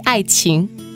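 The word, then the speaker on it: Ai Qing (爱情): Alors, il s'agit d'un duo Eason Shine et Fai Wong avec le titre Yin Wei Ai King. Est-ce que je le prononce bien? – Oui, presque. Yin Wei Ai Qing.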